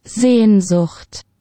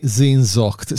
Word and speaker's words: Sehnsucht: Sehnsucht. – Oh, allora, di nuovo. – Sehnsucht. – Eccolo qua, infatti Zinzocht, sì, quello che mi era stato scritto qui si pronuncia Zinzocht.